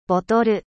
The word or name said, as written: bouteille